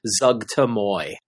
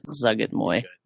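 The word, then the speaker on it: Zuggtmoy: I believe, given the propaganda they handed out to us at Gen Con, it is pronounced Zugtamoy. – Wow. Gauntlet Throne. – I don't really care, and I listened to the audiobook, and that's how the guy pronounced it, so that's got stuck in my head, and that's how I pronounce it, Zugget Moy.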